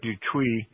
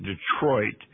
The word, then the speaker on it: Detroit: And then when we got up to Fort Dutuy, actually, most Americans pronounce that differently. They pronounce it Detroit.